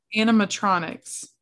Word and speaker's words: animatronics: Think animalectronics? Is that how you say it? – Animatronics. – Thank you, because I butchered that.